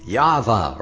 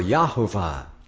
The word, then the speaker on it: Yahweh: And it can be pronounced Yahweh or Yahuwah. Not too much emphasis on the ho there.